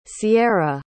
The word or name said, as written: ciara